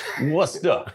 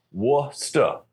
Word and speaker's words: Worcester: Worcester. In Boston. In Western Mass, we sometimes have the ability to pronounce an R and pronounce it Worcester.